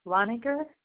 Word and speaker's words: Lengacher: My name is Marilyn Loniger. – Spell it for me. Spell your last name for me. – L-E-N-G-A-C-H-E-R.